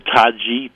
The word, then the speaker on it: Taci: called Taci, T-A-C-I. That's a C that in Turkish is pronounced J.